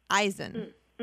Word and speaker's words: Izan: It's spelled I-Z-A-N, but he says it's pronounced I-Z-E-N, Eisen.